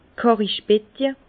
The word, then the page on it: d' Kàrrichbetje: Bas Rhin; Herrlisheim